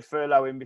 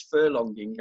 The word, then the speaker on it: furloughing: Most people don't even know how to pronounce it. So most people are referring to it as furlonging, and I keep thinking about the horse races, but, you know, we've got a furlong to go.